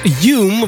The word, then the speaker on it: HVME: En dat spreek je uit als Hume, want dit is een Spaanse DJ en producer. Die zijn naam heeft ontleend aan de Schotse filosoof David Hume.